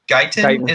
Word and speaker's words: Guyton: And I think I'd even add this guy's been producing a little bit more than what Joe Reed's been doing, but Jalen Guyton, I think, or Guyton is how you pronounce his name.